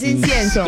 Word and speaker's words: incienso: Ya me confundiste. Incienso. – Incenso. – Es incienso.